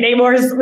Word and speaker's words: Namor: I was so relieved when they started saying Namor, because I was like, because when he said his name, I was like, oh no, I've been saying it wrong. And I've already committed it to memory because I'm really bad at name pronunciations. So I have to change everything I know. But then they went to, I'm like, okay, Namor, we can say it.